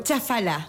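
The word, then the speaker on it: Chafalá: Chafala. – Con Chafala. – Acento en la última, porque si no a veces lo nombran como Chafala.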